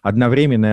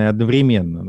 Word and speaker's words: одновременно: Вообще в русском языке есть, например, там равнозначные ударения, да, то есть разные ударения, которые имеют право на существование, например, одновременно и одновременно.